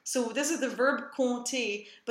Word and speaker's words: compter: In this case, it is silent, so it's compter.